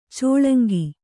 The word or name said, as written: ಚೆಲಂಕು